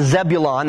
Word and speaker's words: Zebulun: Naphtali and Zebulun, that's the way they're pronounced in the Old Testament.